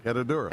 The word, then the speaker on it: Herradura: Herradura. I like how you say it. – Herradura.